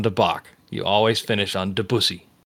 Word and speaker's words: Debussy: Somebody's going to fucking stab me for mispronouncing this name, but Claude Debussy? Debussy? – Debussy.